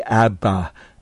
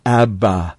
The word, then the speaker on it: Abba: This word Abba in the Aramaic is akin to our word that infants form when they first recognize their father, Dada. It's just two syllables, and in the Aramaic it would be Abba, Abba, Abba.